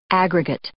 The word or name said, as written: aggregate